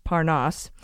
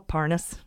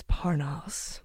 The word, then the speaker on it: Parnas: And by the way, his name is pronounced Parnas, and we just learned this, but guess who knew how to pronounce it a long time ago? – Who?